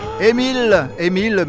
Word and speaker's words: Amiel: Emile, mais ça s'écrit Amiel, mais Emile Cartwright,